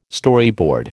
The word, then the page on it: Storyboard: American English